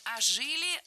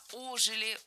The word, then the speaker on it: ожили: Не ожили, а ожили. Ударение в этом слове ставим на приставку.